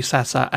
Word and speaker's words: Sasse: So I think that would be Sasser and not Sass. It's spelled S-A-S-S-E.